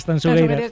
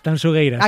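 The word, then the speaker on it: Tanxugueiras: A ver, repítemelo, que me tengo que pronunciar bien. – Tanzugueiras. – Sí, esto me lo corrige, guste mucho a Marta, por favor. Digo, mira que yo siempre pronuncio... A ver, otra vez, dímelo. – Tanzugueiras. – Acaba de deshacerse la manifestación a las puertas de la Gran Vía, de lo bien que lo has dicho.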